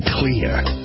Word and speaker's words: Xlear: Introducing Clear. It's pronounced clear, but it's spelled X-L-E-A-R.